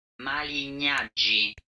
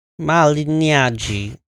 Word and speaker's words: Malignaggi: Malignaggi. – Malignaggi. Pauly Malignaggi fought Artem Lobov, who's a teammate of Conor McGregor's.